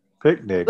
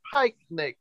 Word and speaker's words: pyknic: P-Y-K-N-I-C. – Picnic. – It kind of sounds like it'd be picnic, but they pronounce it pike-nic.